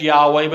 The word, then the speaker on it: Yahweh: And the best way that we think to pronounce that is Yahweh, but it means I am who I am, or I am the one who exists.